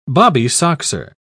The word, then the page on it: bobbysoxer: British English